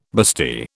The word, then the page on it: bustee: British English